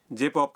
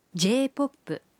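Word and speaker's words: J-POP: جے پاپ کا جاپانی تلفظ ہے جے پاپ، جے پاپ.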